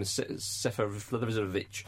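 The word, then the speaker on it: Seferović: Jones let I forget his name, Seferovic in, and I think you'll find that's correct pronunciation.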